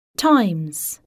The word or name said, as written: times